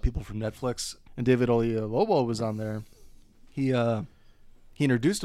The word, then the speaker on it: Oyelowo: See, I think I was pronouncing O, yay, like Y-E, and then Lobo, but it's Y-E-L. Oyelowo.